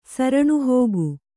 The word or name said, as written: ಸರಣುಹೋಗು